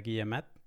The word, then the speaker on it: Guillemet: Guillemet. Supongo que habré destrozado la pronunciación de su nombre, pero. No, no, no perfecto.